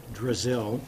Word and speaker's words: Zdrazil: Mr. Chair, members of the committee, my name is Al Drazil, and for the record, I'll spell my name because it's not anything like what it sounds like. It's spelled Z-D-R-A-Z-I-L.